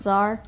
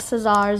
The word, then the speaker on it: Tsar: Yeah, Tsar Peter. The C is silent. Tsar. Tsar. Say that with me. Tsar. – Tsar. – Tsar, not Caesar.